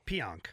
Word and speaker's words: Pionk: They give up a 20th overall pick, Neil Pionk. Is that how you say it? – Pionk. – Pionk? – Mm-hmm.